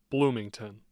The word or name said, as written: bloomington